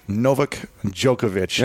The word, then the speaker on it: Novak Djokovic: We have the legendary Novak Djokovic.